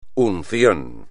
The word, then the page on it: unción: espagnol